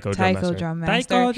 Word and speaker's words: Taiko Drum Master: I don't know, Taiken Drum Master, is that how you say it? – Taiko Drum Master.